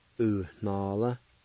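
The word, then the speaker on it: uhnalv: U na la. U na la. U na la.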